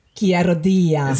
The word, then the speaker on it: Chiarodia: Ähm, dann haben wir ja mitgekriegt, äh, Chiarudia, äh, sag mal mal, wie wird der richtig ausgesprochen? Chiarodia. – Sehr gut.